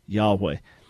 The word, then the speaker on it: Yahweh: So it would be, and the W becomes a V, so it would be Yahweh, which sounds even stranger. But the original pronunciation, as best as we know, Yahweh.